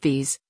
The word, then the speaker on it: Wyss: The Times reports that Wyss, pronounced Vs, is behind the Hub Project, which was first exposed by WikiLeaks in 2016.